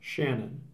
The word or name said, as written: Shannon